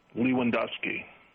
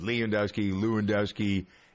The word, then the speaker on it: Lewandowski: How do you pronounce the last name of the person in question that Dwight almost got right? – Lewandowski. – Yeah, that's close enough. Lewandowski.